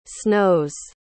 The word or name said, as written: snows